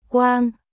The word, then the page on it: Quang: Vietnamese